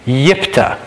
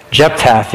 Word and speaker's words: Jephthah: Yes, kind of right in the middle there, you actually pronounce that name Yiptah. Yiptah, believe it or not. I always had a hard time. I would always say Jephthah, you know, which has nothing to do with it.